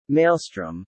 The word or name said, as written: maelstrom